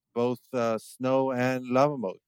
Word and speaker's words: LavaMoat: Lava Mode. So you spell it Lava, L-A-V-A, and then Mode would be M-O-A-T. So just combine them and you get Lava Mode.